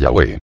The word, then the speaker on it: Yahweh: la pronunciación más correcta sea Yahweh.